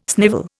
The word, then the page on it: snivel: Indian